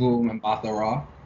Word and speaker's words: Gugu Mbatha-Raw: Gugu Mbatha-Raw.